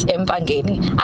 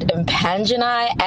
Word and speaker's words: Empangeni: But I really feel like we should just agree on one way to pronounce Empangeni. I've heard people pronounce it Impangeni, and I'm sorry, I just cannot get behind that.